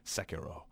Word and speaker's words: Sekiro: That's how you say it, Sekiro.